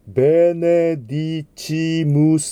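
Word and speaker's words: benedicimus: ラウダームステ、ベネディチムステ。はい、この CI ですが、日本語の小さいのチという音と同じ、非常に完全なチです。チというふうな音にならないようにご注意ください。ベネディチムステ、アドラムステ、グロリフィカムステ。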